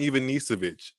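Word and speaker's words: Ivanišević: Goran Ivanisevic.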